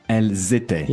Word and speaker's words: elles étaient: But because elle is plural, you make that liaison there. Can you say it? – Elles étaient. – Yeah, the z sound.